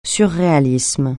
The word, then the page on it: surréalisme: French